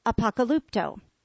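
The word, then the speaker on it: apokalypto (ἀποκαλύπτω): Although the noun is pronounced apokalypsis, the verb is pronounced apokalypto.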